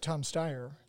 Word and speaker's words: Steyer: Well, you got Tom Steyer. Is that how you pronounce his yeah. name? Yeah. yeah.